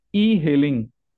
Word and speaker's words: e-hailing: H-A-I-L-I-N-G. – Okay. – E-hailing service. – E-hailing, okay. Interesting. – E-hailing. Yeah, E is separate, and then you pause, and then hailing.